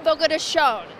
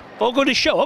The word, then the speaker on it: Fogo de Chão: It's, I hope I'm pronouncing this right, Fogo de Chão? – Fogo de Chão. – Fogo de Chão. Okay, and I hope I'm pronouncing that right.